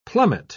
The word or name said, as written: plummet